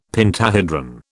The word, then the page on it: pentahedron: British English